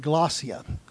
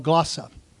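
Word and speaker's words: glossia (γλῶσσα): That's how you pronounce that Greek word, glossia, or glossa.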